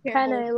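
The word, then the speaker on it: Penei: I think it's like Penae, like Penae vodka sauce.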